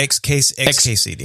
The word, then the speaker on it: XKCD: This was one of those, was it the XCD cartoon? Is that, am I saying that right? Like the nerd cartoon? – Yeah, XKCD.